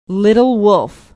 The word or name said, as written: little wolf